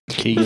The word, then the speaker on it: Keoghan: Barry Keoghan's. I can't say his name. – Keegan. – For, like, is it just Keegan? – I think so.